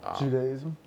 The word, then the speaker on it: Judaism: I feel like when you look at Islam, you look at Christianity, you look at a person who's being Jewish or Judaism, I'm not saying it right. – Judaism? – Thank you.